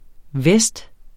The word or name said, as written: vest